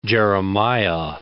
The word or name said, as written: Jeremiah